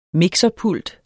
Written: mixerpult

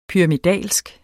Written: pyramidal

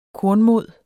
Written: kornmod

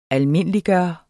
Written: almindeliggøre

